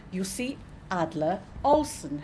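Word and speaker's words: Jussi Adler-Olsen: Okay, so this afternoon, it's my great pleasure to be talking with Jussi Adler Olsson.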